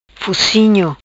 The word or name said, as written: Fucinho